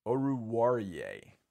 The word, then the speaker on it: Oruwariye: Oruwariye can be tough to say, kind of. And I know how to say it. Mark nailed it, though. Oruwariye. I mean, it's said just like it's spelled, but sometimes in the flow of traffic, it's not Fuller or Cooks or Brandon Dunn.